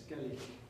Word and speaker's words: Skellig: Je hebt een stuk gemaakt dat heet Skellig Monks. Mm-hmm. Hoe moet ik dat goed uitspreken? – Skellig. – Skellig. Yes. Ja.